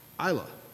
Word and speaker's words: Islay: I won't elaborate too much on it, but he taught me how to pronounce the word that is spelled I-S-L-A-Y. Isla. Isla.